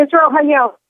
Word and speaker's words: Israel Hayom: She's a senior columnist, again, at Israel Hayom. I'm not saying that right, am I, Caroline? How do I pronounce that? – Yeah, Israel Hayom. – Hayom, okay.